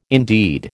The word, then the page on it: indeed: American English